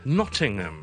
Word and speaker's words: Nottingham: 另外一個好似嘅名咧，就係 Nottingham，亦都係啦，諾定鹹。雖然我哋中文譯翻做有個 H 咁，但係我哋英文讀咧，Nottingham 就可以啦。